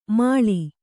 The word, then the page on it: ಮಾಳಿ: kannada